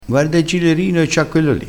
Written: gilerino

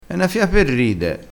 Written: fia

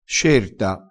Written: scerta

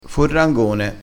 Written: forrangone

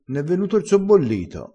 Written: sobbollito

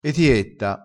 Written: etietta

